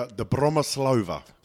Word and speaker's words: Dobromyslova: Dobromyslova.